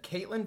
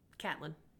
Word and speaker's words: Catelyn: Said Caitlin very mad at her. – No. Catelyn. – Catelyn? – Yeah, let's talk about your pronunciations.